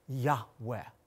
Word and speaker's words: Yahweh: Now the Lord, Yud-Heh-Vav-Heh, oftentimes pronounced with such reverence, a breathy Yahweh.